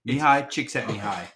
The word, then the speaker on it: Mihai Csikszentmihalyi: The pronunciation of the flow state psychologist, Mihai Csikszentmihalyi. I'm not joking. Mihai Csikszentmihalyi. That's how you pronounce his name.